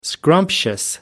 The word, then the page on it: scrumptious: American English